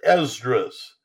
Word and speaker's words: Esdras: Now, I will call it the first one, 3rd Esdras. That's how you say it in Greek, okay? If you were saying it in Hebrew, you would say 3rd Ezra.